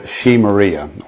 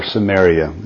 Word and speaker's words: Samaria: And he built a city on this hill and called it Shemaria, or Samaria is the way we pronounce it, after Shemer, the name of the former owner of the hill.